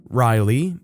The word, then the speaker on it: Ryleigh: My wife suggested C-A-E-L-E-I-G-H, pronounced Kaylee, and yes, she spelled it out. I vetoed it. She suggested another name, R-Y-L-E-I-G-H, Riley, and again, she did spell it out.